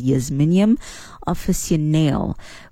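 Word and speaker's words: Jasminum officinale: So you've got the common jasmine, which is the jasminium, not jasminium, jasminium. It's pronounced as jasminium officinale, which is the one most people think of.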